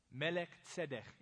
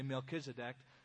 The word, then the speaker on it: Melchizedek: Melech tzedek is the Hebrew pronunciation. We say Melchizedek because not too many people can walk around saying melech tzedek.